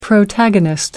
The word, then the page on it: protagonist: American English